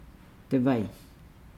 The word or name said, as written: de vai